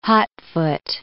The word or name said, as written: hotfoot